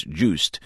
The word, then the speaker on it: Joost: That's Janice Fries, the creator with Nicholas Zennstrom of the new online video service, Juiced, which is spelt, by the way, J-O-O-S-T.